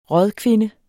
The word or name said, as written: rådkvinde